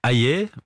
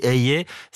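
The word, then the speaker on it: Hayer: Bon, bah, Valérie Hayet, parce que c'est comme ça qu'on dit, et même la prononciation de son nom a fait preuve de débat. Paraît-il même qu'il faudrait dire Hayet. Valérie Hayet, c'est un peu un Kinder Surprise.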